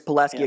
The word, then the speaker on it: Pulaski: It's Pulaski. Pulaski County is how it's pronounced. – Okay, then it's Pulaski. I used to live there. Then it's Pulaski Academy.